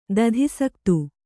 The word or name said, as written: ದಧಿಸಕ್ತು